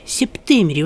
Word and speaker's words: Септемерий: Септемерий, вот так ударение стоит – Септемерий.